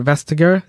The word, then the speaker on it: Vestager: And you cite people like Margaret Vestager. Is that how you pronounce her name? – Yeah.